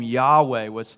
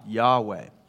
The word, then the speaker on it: Yahweh: He says his name, Yahweh, is our best way of pronouncing the name of God, Yahweh.